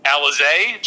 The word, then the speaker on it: Alize: Yeah, Justice Johnson, who is, I believe it's pronounced Alize Johnson.